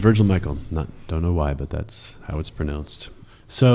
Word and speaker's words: Michel: Do not say, Father Virgil Michel. – Oof. Oh, what do you say? – His name is Virgil Michael, even though it's spelled M-I-C-H-E-L.